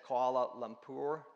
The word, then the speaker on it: Kuala Lumpur: And I always say Kuala, but that's not how you say it. Kuala Lumpur.